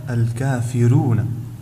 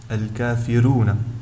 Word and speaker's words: الكافرون: فحرف الراء الذي سأتمم به في كلمة الكافرون، فهنا جاء قبل حرف الراء كسر اللازم، وبالتالي فإن الراء تكون هنا مرققة عند ورش وتنطق هكذا الكافرون، ويجب إظهار ترقيقها الكافرون.